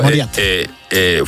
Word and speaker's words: Moriarty: Pero claro, el tal Moriarty es Mauriato, no sé cómo se pronuncia Moriarty, esto. – Moriarty.